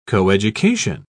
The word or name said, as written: coeducation